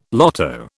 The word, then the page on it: lotto: British English